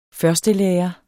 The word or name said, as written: førstelærer